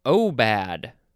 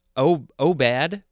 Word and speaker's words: aubade: Next, we have obad. Oh, I am so bad at pronouncing these words, but I think that's how it's pronounced. Obad. It is spelled A-U-B-A-D-E.